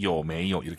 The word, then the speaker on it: 有没有: 요메이 이 발음 따라하시면서 요메이요 이렇게 발음하세요. 요메이요 아니에요 여러분.